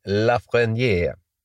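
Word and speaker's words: Lafrenière: Lafreniere.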